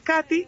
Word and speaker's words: Katie: Entonces, Katy se escribe K-A-T-I-E, con la I de iglesia normal. Entonces, no tiene H, no tiene Y, nada raro. K-A-T-I-E.